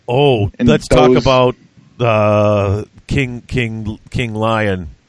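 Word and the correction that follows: Louen: King Lewin.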